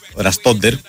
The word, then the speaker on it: Ραστόντερ: Δεν τον ήρθε και ο Ραστόντερ. Έτσι τονίζεται, έτσι είναι το σωστό.